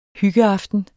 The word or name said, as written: hyggeaftener